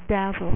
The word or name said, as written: dazzler